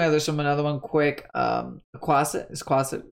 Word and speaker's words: Quasit: Quasit.